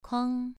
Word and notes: kuang: 2

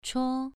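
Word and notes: chuo: tone 2

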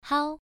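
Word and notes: hao: tone 1

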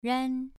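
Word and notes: ran: tone 2

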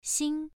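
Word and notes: xing: tone 1

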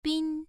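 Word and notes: bin: tone 1

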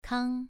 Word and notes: kang: 2

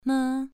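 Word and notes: me: tone 2

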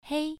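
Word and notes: hei: tone 1

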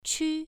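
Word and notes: qu: tone 1

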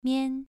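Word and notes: mian: tone 2

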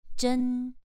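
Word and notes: zhen: tone 2

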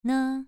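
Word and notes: ne: tone 2

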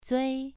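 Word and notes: zui: tone 2

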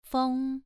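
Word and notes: feng: tone 2

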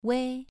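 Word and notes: wei: tone 2

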